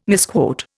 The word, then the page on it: misquote: Indian